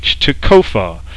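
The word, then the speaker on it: tequphah: Now the Hebrew word for end is tekufa, and I'll spell that. It's T-E-Q-U-P-H-A-H, tekufa.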